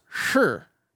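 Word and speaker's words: sure (是): Like in mainland China, the way they say yes is the word is pronounced sure, sure.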